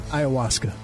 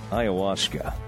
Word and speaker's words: ayahuasca: Ayahuasca. – Ayahuasca. All right. Do you feel like the show would be better if I tried some ayahuasca?